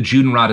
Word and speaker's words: Judenrat: There's an organization called the Judenrat, which is rot is like it's spelled rat, like the animal, but it's like a governing council, right?